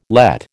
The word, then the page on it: Lett: American English